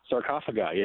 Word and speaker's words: sarcophagi: Sarcophagi? – Sarcophagi, yeah.